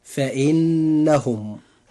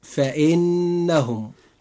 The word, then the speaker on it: فإنهم: وفي هذه الحلقة إخوة الإسلام قرأنا معا الآية 6، وجاء قوله تعالى: إلا على أزواجهم أو ما ملكت أيمانهم فإنهم غير ملومين. – نجد النون في كلمة فإنهم، هذه النون مشددة، وهذه النون يجب إظهار غنها بمقدار حركتين، فننطقها هكذا: فإنهم.